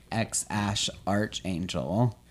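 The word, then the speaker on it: X Æ A-Xii: Okay, Grimes and Elon Musk had a baby, and the baby's name is like incomprehensibly spelled, but it's pronounced like ex Ash Archangel, and they just call him X.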